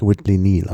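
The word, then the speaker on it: Whitley Neill: Nochmal zur, ich habe jetzt gerade nachrecherchiert, also es wird wirklich Whitley Neal ausgesprochen. – Okay, Whitley Neal, okay. – Ja. Das ist das Doppel-L bei Neal ist nur eine Schreibvariante, aber sonst der Vorname Neal, der ja keltischen Ursprungs ist. Und der wird Neil ausgesprochen, da gibt es gar kein Vertun.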